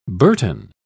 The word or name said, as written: burton